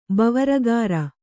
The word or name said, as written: ಬವರಗಾರ